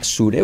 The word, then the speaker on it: sure: Curioso, es una sure, una S, pero se pronuncia como una SH, right? – That's right. Sure.